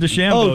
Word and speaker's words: DeChambeau: It's Bryson DeChambeau.